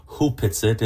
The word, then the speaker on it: Hupetsit: It's written Opetsit, but it's actually pronounced properly Hupetsit, and the root word is hupa.